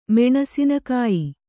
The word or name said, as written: ಮೆಣಸಿನಕಾಯಿ